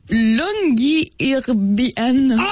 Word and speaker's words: Longyearbyen: OK, c'est quoi la vas-y donc pour sa prononciation? – Ça s'appelle Longyearbyen. – T'es passé tellement proche, mon gars. – Je pense que c'est vraiment la prononciation exacte.